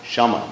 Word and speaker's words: shama: Especially this quality of sama, or shama, actually, I'm not pronouncing it right, shama, which means being equal poised.